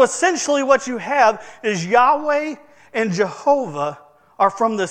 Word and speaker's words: Yahweh: So later in time, when the Hebrews started placing vowels into the words, they came up with a word that was pronounced Yahweh.